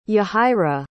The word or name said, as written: yahaira